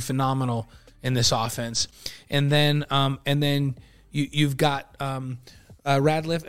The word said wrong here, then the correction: Retzlaff: Am I saying it right? – Retzlaff. Yeah, we'll all get used to it.